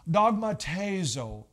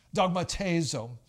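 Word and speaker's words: Dogmatezo (δειγματίζω): Dogmatezo is how it's pronounced in the original language. Dogmatezo.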